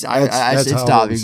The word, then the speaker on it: Davi: It's pronounced Davi, right? – Yeah, it's Davi.